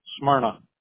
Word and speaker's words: Smyrna: We're obviously going to get you found in your local area of Smyrna. Am I pronouncing that right? Smyrna? – Don't overthink it. Smyrna. – Smyrna. Okay. – Pronounce it like it has a U instead of a Y.